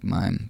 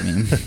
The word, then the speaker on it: Mime: M I M E, which looks like mime, but mime. it's pronounced meme.